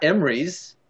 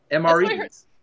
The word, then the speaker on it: MREs: Not Emrys, MREs.